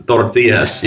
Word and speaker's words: tortillas: Tortillas.